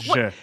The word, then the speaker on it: je: I think je is pronounced je, Gabe. As you were, Kath.